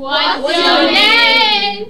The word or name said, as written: what's your name